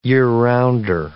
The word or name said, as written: year-rounder